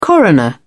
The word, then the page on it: coroner: British English